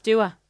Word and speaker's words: Dua: It is Dua Lipa. – So I say Dua Lipa. – No, it's Dua. – That's Dua. – It's 100% Dua. – It's spelled J-E-W-A.